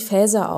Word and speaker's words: Faeser: Die hessische Fraktionschefin Nancy Faeser, spricht man das so aus? – Ich glaube, ehrlich gesagt, man spricht sie Faeser aus. Also sagen wir mal alle Faeser, aber es ist ja nicht Englisch.